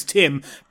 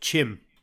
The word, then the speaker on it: Tim: And for some reason, they say T's with like a CH. So my name's Tim, but they pronounced it Chim.